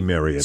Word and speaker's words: Marriott: It's supposed to rhyme with chariot. – So it should be Marriott.